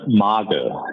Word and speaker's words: Magel: And for listeners, why don't you give the German pronunciation of your last name? – That's Magel, you know. So, watch out, Magel. Magel. Well, both good for me.